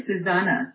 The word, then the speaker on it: Susanna: When I see it written, I would say Suzanne, but I did listen to Rick Archer. He called you Susanna. – It's actually Susanna.